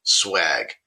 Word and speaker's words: schwag: According to Merriam-Webster, the freebie swag, sometimes also spelled S-C-H-W-A-G, dates back to the 1960s and was described to use promotional items. So it is spelled the other way, but the pronunciation is swag.